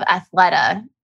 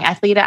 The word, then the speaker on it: Athleta: So I love Athleta, and I am probably pronouncing that wrong. Athleta, Athleta. I've heard it both ways, but they are a women's clothing brand that really cares about quality.